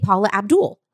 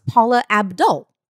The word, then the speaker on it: Paula Abdul: Yeah, we've been saying Paula Abdul. It's Paula Abdul.